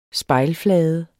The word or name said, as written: spejlflade